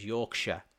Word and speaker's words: Yorkshire: But over the centuries, instead of pronouncing it Shire, it was just Yorkshire. And we have like Lancashire. But it's spelt Shire, but it's not pronounced Shire anymore because we are not shires any longer. We are like counties. So it is Yorkshire. But it is from Shire.